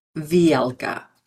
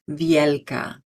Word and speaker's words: Vielka: So first, let's start with the pronunciation. Vielka, Vielka. Or if you want to say it the way it originally was said, use a Spanish accent. Vielka, Vielka.